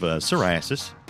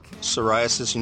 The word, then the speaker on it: psoriasis: You know the weirdest thing about psoriasis, and you probably know this. – Yeah. – The weirdest thing about it is that it's spelled with a P.